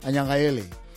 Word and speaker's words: Anyangayele: It's called Anyangayele. Yeah. Am I saying that right? – Perfect. You said it really.